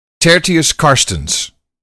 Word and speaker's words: Tertius Karstens: Anyway, I didn't – tell us what the real pronunciation of this name is if it was Dutch. – That would be Tertius Karstens.